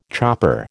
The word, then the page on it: chopper: American English